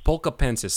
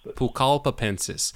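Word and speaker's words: pucallpaensis: Is that how you pronounce it? Pacula? – Pulcapensis. – So, Pulcapensis.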